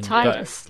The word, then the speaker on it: Titus: That is the official pronunciation. – Titus, like the tide.